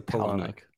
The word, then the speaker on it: Palahniuk: How do you pronounce it? – I say Palahniuk.